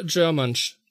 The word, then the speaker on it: Germench: Germansch. – Germansch, also, also wie halt German und. G German plus French gleich Germansch.